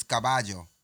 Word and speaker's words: caballo: It's the double L's. It's caballo.